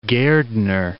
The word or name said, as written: Gairdner, Lake